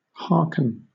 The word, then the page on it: harken: British English